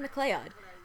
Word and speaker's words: MacLeod: John Bell, on the other hand, Kevin MacLeod's name is spelled MCLEOD, which looks like Kevin MacLeod, but, and plus, I've heard it on other shows pronounced apparently incorrectly, and my friend Renee Christine Jones told me, oh, it's actually pronounced Kevin MacLeod,